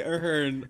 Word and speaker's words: Ahern: This Ahern.